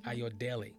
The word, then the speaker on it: Ayodele: Ayodele.